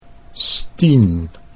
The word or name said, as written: Stien